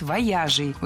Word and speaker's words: вояжей: Выезжей – неверно. Ударение на окончание переносить не следовало. Правильный вариант – вояжей. Ударение всегда сохраняем на «я» во втором слоге. Вояжи, вояжей, вояжами.